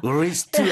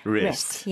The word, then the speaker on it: wrist: W로 시작한다고 뭔가 강하게 W 소리 안 내도 돼요. – wrist 아닙니다. 그냥 wrist.